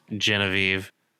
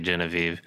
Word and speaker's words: Genevieve: Am I pronouncing her name right? – Genevieve. – Sorry, Genevieve.